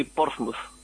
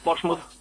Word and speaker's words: Portsmouth: Escucha, ¿cómo se pronuncia? Porque yo he oído que se dice Plymouth y Bournemouth. – Es Plymouth, Bournemouth y Portsmouth. – Portsmouth. – Sí, Portsmouth.